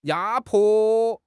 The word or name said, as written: 压迫